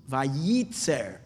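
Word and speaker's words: Vayitzer (וייצר): But interesting, the second one is not pronounced. The first one is pronounced. Vayitzer. The second Yud is hidden. It's not pronounced.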